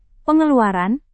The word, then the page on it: pengeluaran: indonesian